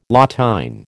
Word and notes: La TÊne: American English

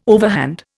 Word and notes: overhand: Indian

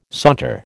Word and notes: saunter: American English

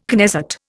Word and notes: Knesset: Indian

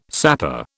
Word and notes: sapper: British English

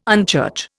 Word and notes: unchurch: Indian

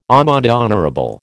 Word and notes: amende honorable: American English

